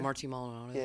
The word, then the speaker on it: Maldonado: Martin Maldonado.